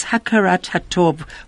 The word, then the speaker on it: hakarat hatov: I've just checked with you how to pronounce it, and it's hakarat hatov, which literally means recognizing the good.